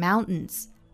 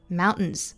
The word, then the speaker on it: mountains: 说到这个 mountains，其实我们讲到了 t 呢，这个发音组合呢，其实是可以发成鼻爆音的，也就是发成哼哼的感觉。这个单词其实可以发成 mountains， mountains。我们也经常听到这个英国人、美国人读成 mountains。